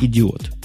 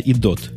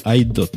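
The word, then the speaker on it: iDO: Вначале читается как «идиот», но в самом деле это оказывается «идот», «айдот» даже – да, идот это там, если была бы буквочка Е все-таки.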